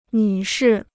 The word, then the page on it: 女士: nǚ shì